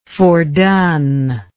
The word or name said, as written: foredone